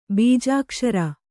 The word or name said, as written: ಬೀಜಾಕ್ಷರ